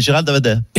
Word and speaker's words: Gérald Darmanin: Pardon, vous n'avez pas le prononcé mon nom. Mon nom, c'est Gérald Darmanin. – Darmanin. Oui. – Darmanin, D-A-B-A-D-I-N.